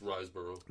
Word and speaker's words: Riseborough: Andrea, I'm going to mispronounce this, Riseborough? – It's Riseborough.